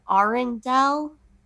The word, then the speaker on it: Arundel: He was born in Arundel.